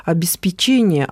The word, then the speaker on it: обеспечение: Подскажите, пожалуйста, часто говорят слова «обеспечение», а мне хочется сказать с ударением на второе «обеспечение». Как правильно? – Да, это правильно, хотя вот первый вариант, он сейчас очень сильно распространен, но в данном случае у нас есть подстраховка. Мы помним, что глагол звучит как «обеспечить», и производное существительное будет звучать с тем же ударением.